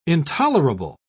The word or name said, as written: intolerable